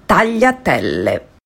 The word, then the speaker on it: tagliatelle: Finally, I said I ordered the brown butter tagliatelle, and she told me that I should have ordered the tagliatelle. – Tagliatelle. You could hear the judgment in her voice. Yeah. She was like, exhausted by me. This is how you pronounce it. Tagliatelle.